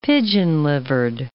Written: pigeon-livered

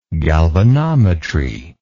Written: galvanometry

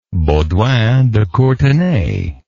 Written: baudouin de courtenay

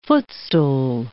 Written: footstool

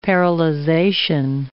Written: paralyzation

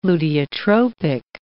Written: luteotropic

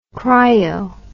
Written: Kryo

